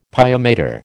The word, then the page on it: pia mater: American English